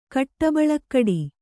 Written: ಕಟ್ಟಬಳಕ್ಕಡಿ

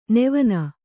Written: ನೆವನ

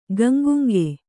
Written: ಗಂಗುಂಗ

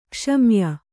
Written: ಕುಸಮಯ

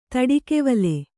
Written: ತಡಿಕೆವಲೆ

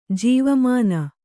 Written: ಜೀವಮಾನ

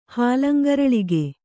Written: ಹಾಲಂಗರಳಿಗೆ

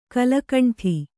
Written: ಕಲುಗುಂಡು

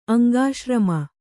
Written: ಅಂಗಾಶ್ರಮ

